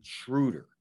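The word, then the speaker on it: Schröder: It says the double dot, the dot's on top, and he's German. Schroeder.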